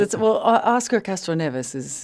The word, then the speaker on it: Oscar Castro Neves: Did I pronounce his name correctly? He's an important guy. – Depending if you're from Rio or or São Paulo, they tell me. But yes, Oscar Castro Neves is kind of the more American. You were right.